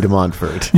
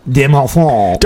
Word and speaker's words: de Montfort: And one of the great things about this particular saint we're going to talk about, say his name so I can correct you. – Saint Louis de Montfort. – Yeah, okay, so De Montfort.